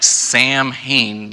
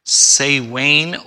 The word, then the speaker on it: Samhain: it looks like Samhain, but that's not how you pronounce it. You pronounce it Samhain or Samhain festival,